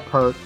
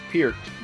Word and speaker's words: Peart: I believe the correct pronunciation is Peart, but, you know, everybody outside of Canada calls him Neil Peart, so I'll accept either or.